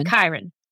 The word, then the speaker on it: Chiron: And he ends up in the woods with, how am I going to pronounce his name? – Chiron. Yeah.